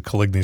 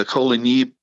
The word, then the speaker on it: Coligny: And the Coligny French pronunciation equates to Coligny in English.